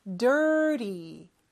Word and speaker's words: dirty: 그래서 water가 water가 되는 것처럼 저 T 발음은 real과 비슷하게 혀가 입천장에 살짝 닿았다 떨어지는 그러한 표현입니다. dirty, dirty 이렇게 발음하면 돼요.